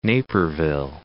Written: Naperville

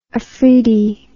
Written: afridi

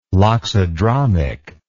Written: loxodromic

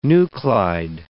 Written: nuclide

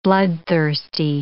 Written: bloodthirsty

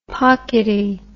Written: pockety

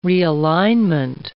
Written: realignment

